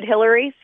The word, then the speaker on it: Hillery: I'm the good Hillary. So my first name is spelled H-I-L-L-E-R-Y. Last name is Brochol, B-R-O-T-S-C-H-O-L.